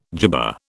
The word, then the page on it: jibba: British English